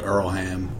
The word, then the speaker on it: Earlham: It's spelled Earlham, pronounced Earlham. At least that's the way I've always pronounced it.